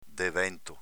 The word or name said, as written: devento